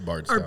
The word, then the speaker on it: Bardstown: It's Bardstown.